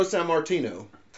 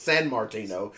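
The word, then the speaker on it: Sammartino: Not gonna lie, just realized there was no N in that during the reading the Meltzer obituary. I probably had been pronouncing it San Martino my whole life because that sounds like a name.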